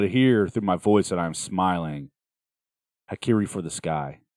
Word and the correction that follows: Harakiri: Harakiri, H-A-R-A. K-I-R-I, for the Sky, Harakiri.